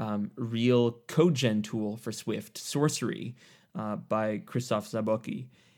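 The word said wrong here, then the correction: Zabłocki: And you know, Zablotsky. – Okay, sure. I'll let you pronounce it. – He was a guest on the podcast, so we spent mm-hmm. quite some time trying to learn how to pronounce that.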